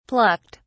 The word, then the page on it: plucked: British English